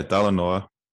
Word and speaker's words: Talanoa: What does Talanoa mean? Am I pronouncing that right? Yeah, Talanoa. You did fine.